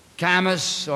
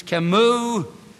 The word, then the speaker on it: Camus: Camus, or as the correct pronunciation in the French is, Camus, named suicide as the only truly serious philosophical problem.